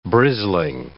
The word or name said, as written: brisling